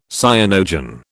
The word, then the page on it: cyanogen: British English